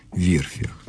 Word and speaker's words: верфях: И как ставится ударение «верфях» или «верфях»? Ударение сохраняется на первом слоге, на Адмиралтейских верфях.